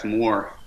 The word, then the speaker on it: Mohr: I'm taking it I've pronounced your surname correctly. I haven't butchered it. – That's correct, Mohr.